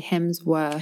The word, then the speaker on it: Hemsworth: And in British English, we pronounce it differently, obviously, because of that R sound. Bit, yeah. yeah. – So I would say Hemsworth. – Hemsworth, which is probably more accurate since it's Australian, right?